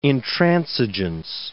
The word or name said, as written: intransigeance